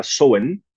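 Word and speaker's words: Samhain: Halloween proviene de un festival celta en Irlanda, en Escocia, en la isla de Man, que se llamaba Samaín, o pronunciado en, celta era Soen a pesar de estar escrito Samaín.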